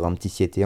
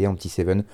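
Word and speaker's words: Empty 7: Alors je vais dire Empty Set tout le long, mais apparemment on peut dire Empty Siete, Empty Seven. Ça s'écrit Empty comme vide en anglais, et le chiffre 7.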